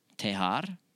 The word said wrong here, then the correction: dejar: How do you say, is it D-E? – D-E-J-A-R. – Dejar.